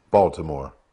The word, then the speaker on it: Baltimore: Baltimore, where we don't pronounce the T.